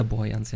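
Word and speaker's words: Walter-Borjans: Walter Borjans, ja genau.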